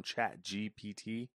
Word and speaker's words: ChatGPT: Microsoft plans to incorporate artificial intelligence products from ChatGPT, GPT, I think I said that right? – Yep, you did.